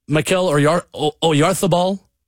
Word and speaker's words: Mikel Oyarzabal: And Mikel Oyarzabal, the right way to say it. I love doing the lisp.